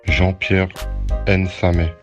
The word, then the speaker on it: Jean-Pierre Nsame: Also der Donat hat geschrieben, der Jean-Pierre Nzame werde wohl Nzame ausgesprochen, weil das Aigu bei der kamerunische Minorität nicht ausgeschrieben wird. Und tatsächlich ist also in der Bantu-Spruch Duala ist das halbgeschlossene E, wird als E mit Aigu ausgesprochen. Und unsere Kollegen,